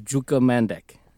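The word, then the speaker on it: Đuka Mandić: I looked up the pronunciation for this woman's name, and it was Juka Mandic.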